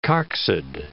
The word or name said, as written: coccid